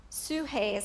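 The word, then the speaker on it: suhe (ψυχή): There's two Greek words used for life, Zoe, and then the other one is suhe, and it looks like psyche. Okay, it looks like psyche, and I believe that's on your handout, and I've got it on the slide. Suhe is how it's pronounced.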